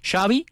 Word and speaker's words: Xavi: ¿Cómo se pronuncia? Se lo dice en inglés Xavi Hernández. Entonces Xavi dice, Xavi como chocolate.